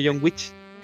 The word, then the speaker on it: John Wick: Eh, es, muy bonita, a mí me recordó al, famoso, al famoso John Wick, ahora sí lo dije bien, no dije John Wick.